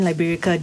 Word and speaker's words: Liberica: Have I butchered that name? No. – Because I'm thinking Liberica.